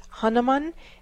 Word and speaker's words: Hanuman: His name is Hanuman. That's spelled H-A-N-U-M-A-N.